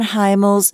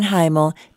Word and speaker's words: Hymel: Okay, so that reminds me of what I'm going to play now, a song from Don Heimel's CD. Don is a Texas singer-songwriter, and I hope I'm saying his name right. It's Don, H-Y-M-E-L.